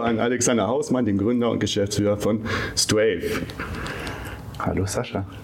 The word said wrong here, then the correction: Strive: Habe ich Strive richtig ausgesprochen? Strive. Strive, siehst du?